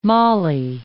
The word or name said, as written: mollie